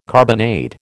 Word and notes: carbonade: American English

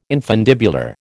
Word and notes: infundibular: American English

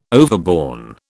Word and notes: overborne: British English

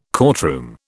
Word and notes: courtroom: British English

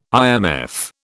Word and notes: IMF: British English

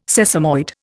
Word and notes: sesamoid: Indian

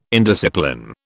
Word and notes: indiscipline: British English

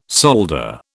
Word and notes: solder: British English

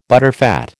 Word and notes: butterfat: American English

